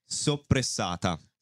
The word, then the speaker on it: soppressata: Like soppressata.